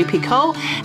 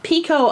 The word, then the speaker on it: Picoult: Picoult, I believe, Picoult is how you pronounce it, so I apologize to the universe and to Jodi.